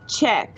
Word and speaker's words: Czech: Okay, so it's Che, Czech. – Czech. – So it's C H C H E Che, and then Czech, like the word Czech, C H.